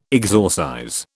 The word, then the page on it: exorcize: British English